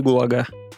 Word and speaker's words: ГУЛАГ: Как, куда ударение ставится? Гулаг. Да. да. Спасибо. В музей ГУЛАГ. Ну? ГУЛАГ. Ну. Будем на первый тогда слог говорить.